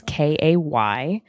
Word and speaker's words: Kaytlin: You should follow me at Kaytlin Bailey. My parents spelled my name wrong. It is Kaytlin. Bailey, B-A-I-L-E-Y.